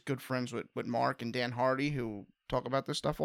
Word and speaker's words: Dan Hardy: His name is pronounced, I was asking GSP about this, Dan Hardy. – Oh, my bad. – Dan Hardy.